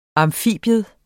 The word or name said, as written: amfibium